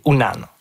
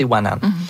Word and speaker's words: Unan: Puis le vrai mot Innu, c'est Unan, ou parfois prononcé Wanan.